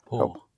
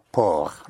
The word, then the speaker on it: port: How would you pronounce P-O-R-T? – P. – P-O-R-T. – Po. – Yes, say it again. – Po. – Por, right. So again, the consonant at the end of a word is not sounded unless it is followed by an E. So por, P-O-R-T, por.